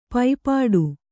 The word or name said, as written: ಪೈಪಾಡು